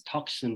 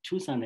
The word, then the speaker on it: Tucson: And I used to pronounce as Tucson because that's what I thought, like T-U-C-S-O-N, you know? So when I came to Tucson and started studying at Catalina, that's when I realized it's called Tucson.